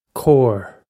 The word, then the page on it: cór: Irish